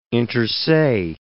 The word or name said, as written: inter se